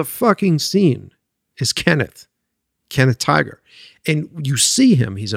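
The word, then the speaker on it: Tigar: It's T-I-G-A-R. It's T-Gar Tiger. I've never said his name, but I love that guy.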